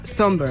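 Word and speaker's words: Sombr: Pues mira, yo busqué cómo se pronunciaba por si acaso, pero efectivamente se pronuncia Somber.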